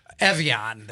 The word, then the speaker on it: Evian: Do you remember Avion? Have you seen Avion? – You mean Evian? – Evian.